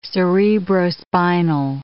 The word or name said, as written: cerebrospinal